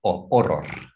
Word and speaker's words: horror: So the H is silent. Oh, horror. – Oh, horror. Okay.